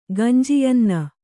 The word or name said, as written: ಗಂಜಿಯನ್ನ